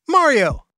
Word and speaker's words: Mario: It starts with an M and rhymes with Barrio. That's right, Mario.